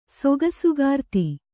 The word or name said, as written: ಸೊಗಸುಗಾರ್ತಿ